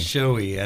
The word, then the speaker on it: Shohei: I think it's Shohei. I don't think you actually pronounce the H.